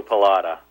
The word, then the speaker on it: Pallotta: It's with a short O, Pallotta.